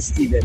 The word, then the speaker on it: Stephen: Cioè, scritto STFHN, vabbè, insomma, quello che a me mi sembra più Stephen che Steven. – Però la pronuncia è Steven.